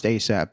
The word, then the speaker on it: ASAP: Pronounced ASAP, but it looks like ass app.